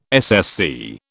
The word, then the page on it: SSC: British English